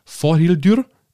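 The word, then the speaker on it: Þórhildur: Also bei Magrede Alexandrine Vorhildür. Ingrid, denn Vorhildur ist tatsächlich ein isländischer Name. Hast du eine Ahnung, ob ich das jetzt richtig ausgesprochen habe? – Ja, das hast du, aber isländisch ist der Name nicht.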